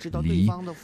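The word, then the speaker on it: 梨: Man sollte kein Obst mitbringen, das von der Aussprache klingt wie das chinesische Wort für Trennung. Hier in Nordchina keine Birne zum Beispiel, auf Chinesisch Li.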